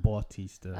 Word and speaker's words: Bautista: I watched My Spy with Dave Bautista. Is that how you say his name? – Yep. – Yep. – Well, I think it's actually pronounced Bautista.